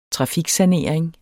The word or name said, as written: trafiksanering